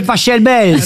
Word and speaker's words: Pachelbel: Ça s'écrit P-A-C-H-E-L-B-E-L. – Mais il adorait l'Italie. – Alors, par contre, s'il est allemand. – Cannelloni, c'est Pachelbel. – S'il est allemand, ça se dit Pachelbel.